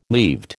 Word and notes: leaved: American English

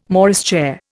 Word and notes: Morris chair: Indian

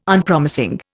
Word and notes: Unpromising: Indian

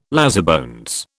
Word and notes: lazybones: British English

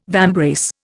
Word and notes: vambrace: Indian

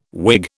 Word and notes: Whig: British English

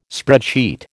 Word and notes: spreadsheet: American English

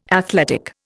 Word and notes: athletic: Indian

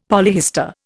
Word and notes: polyhistor: Indian

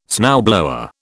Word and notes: snowblower: British English

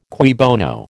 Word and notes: cui bono: American English

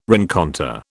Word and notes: rencontre: British English

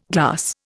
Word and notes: glass: Indian

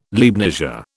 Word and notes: Leibnizia: British English

